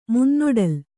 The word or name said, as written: ಮುನ್ನೊಡಲ್